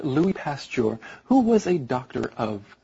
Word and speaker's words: Pasteur: That's P-A-S-T-U-R-E. And his name sounds a lot like that, except the emphasis is on the second syllable, so it's Pasteur.